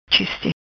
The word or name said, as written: Chiste